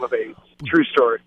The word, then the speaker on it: Ades: Their name is ADES, pronounced AIDS, okay? – Yeah.